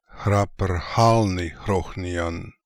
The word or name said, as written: Khraprkhalnikhrokhnian